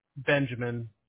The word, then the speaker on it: Benjamin: Benjamin, B-E-N-J-A-M-I-N. – Perfect. – The middle initial, Vandevort, V-A-N-D-E-V-O-R-T.